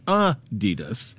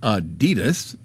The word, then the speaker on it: Adidas: From our You've Got to Be Kidding Me file, apparently we've been pronouncing Adidas wrong all this time. It's supposed to be Adidas, with a slight emphasis on the first syllable, not Adidas, stressing the second.